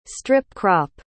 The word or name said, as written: strip-crop